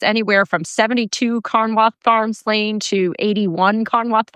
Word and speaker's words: Carnwath: I'm also going to spell Carnwath because I realize it sounds different than what it looks like. So Carnwath is spelled C-A-R-N-W-A-T-H.